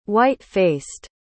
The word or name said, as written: white-faced